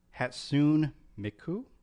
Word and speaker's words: Hatsune Miku: An interesting note that I wrote down was in Japan there's a singer named Hatsune Miku.